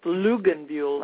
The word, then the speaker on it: Lugenbuhl: My husband's real Swiss name, if it isn't Luckinbill, would be pronounced Luckinbill, like L-U-G-E-N-B-U-H-L.